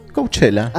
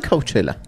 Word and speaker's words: Coachella: Coachella. – ¿Así también? – Coachella.